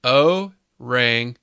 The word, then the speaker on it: orangutan: So the proper way to say orangutan, or it should be said, orangutan. – So orangutan. Right? I like it. – Yeah. Yeah, there's no G at the end, so.